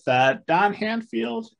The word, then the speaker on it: Handfield: I'm chatting with Don Handfield and Joshua Malkin.